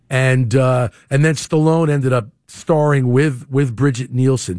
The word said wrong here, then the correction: Brigitte Nielsen: Brigitte Nielsen is the actual correct pronunciation to say her name.